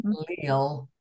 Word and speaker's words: Lael: And her middle name is spelled L-A-E-L. So Linda Lael Miller. – If you it's Lael. It rhymes with sail and jail.